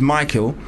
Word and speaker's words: Mikill: A similar problem because my name is Michael, but it's spelled M-I-K-I-L-L, and a lot of people will call me Mikhail.